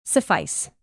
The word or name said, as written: suffice